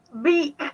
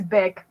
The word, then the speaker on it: Bic: It's like the pen brand. Beak. You know what I'm talking about? It's Bic. I was going to say, I'm pretty sure you pronounce it Bic.